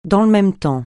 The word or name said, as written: dans le même temps